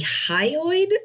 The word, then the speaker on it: hyoid: One is the, I don't know if I'm saying it right, the hyoid bone.